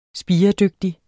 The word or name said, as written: spiredygtig